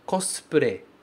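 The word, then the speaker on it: Cosplay (コスプレ): Cosplay, ok? Tá aqui escrito em katakana. Cosplay. Lembrando que esse U do cos, a gente não vai pronunciar. Cosplay.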